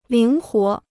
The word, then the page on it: 灵活: líng huó